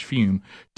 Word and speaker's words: Fume: Now, it's spelled F-U-M, but it's pronounced Fume.